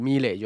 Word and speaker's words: milet: 那 milet 的话，那个英文拼音是 M I L E T 嘛，那因为。因为他这个发音是我忘记是哪里了，milet，我之前有在他采访有看过说，说呃不知道哪一个国家的名字，那呃因为他后面那个 L E T 的那个 T 不发音嘛，所以如果你要念他的名字的话，就是 milet 就好。